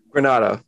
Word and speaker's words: Granato: Is it Granato? – Granato.